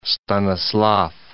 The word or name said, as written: Stanislav